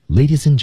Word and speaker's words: ladies and: 开场的时候啊，我们看这句话的节奏是比较复杂的，大家注意一下啊，Ladies and gentlemen。 Ladies and 连读，ladies and D 失去爆破，ladies and gentlemen，所以一二三前三个单词组合在一起的读音尽人皆知，读好的不多，能读漂亮的更难，所以光这三个词就够大家练一段时间的。